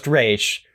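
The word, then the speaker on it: Ra's: Just Raish. And yes, that's how it's supposed to be pronounced.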